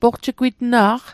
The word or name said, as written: poqjigwitna'q